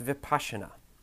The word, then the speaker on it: Vipassana: It's pronounced Vipassana.